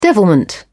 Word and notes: devilment: British English

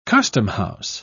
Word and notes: customhouse: British English

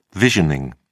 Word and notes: visioning: British English

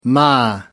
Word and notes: ma: American English

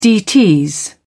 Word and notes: dts: British English

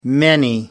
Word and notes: many: American English

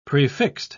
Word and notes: prefixed: British English

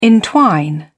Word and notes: entwine: British English

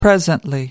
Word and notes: presently: American English

